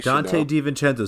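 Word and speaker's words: Donte DiVincenzo: You Dante DiVincenzo.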